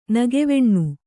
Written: ನಗೆವೆಣ್ಣು

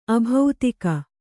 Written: ಆಬ್ದಿಕ

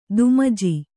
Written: ದುಮಜಿ

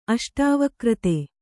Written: ಅಷ್ಟಾವಕ್ರತೆ

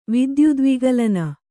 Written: ವಿದ್ಯುದ್ವಿಗಲನ

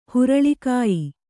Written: ಹುರುಳಿಕಾಯಿ

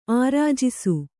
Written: ಆರ್ಜಿಸು

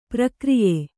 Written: ಪ್ರಕ್ರಯ

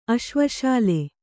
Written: ಅಶ್ವಶಾಲೆ

